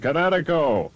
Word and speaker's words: Kinetico: Everybody knows that Kinetico is spelled K-I-N-E-T-I-C-O. – I thought the instructor said Connecticut, not the East Coast. – Son, you should have remembered kinetic as in kinetic energy with an O on the end. Kinetico. – Kinetico. Spell it K-I-N-E-T-I-C-O.